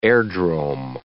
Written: airdrome

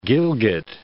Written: Gilgit

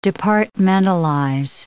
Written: departmentalize

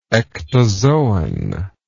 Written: ectozoan

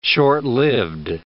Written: short-lived

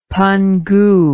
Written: Pangu

